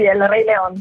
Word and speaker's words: El Rey León: A Madrid ha interpretato Nala nel cast originale della musical Disney El Rey León. Se la pronuncia è corretta, se no mi correggi tu. – Sì, sì, El Rey León.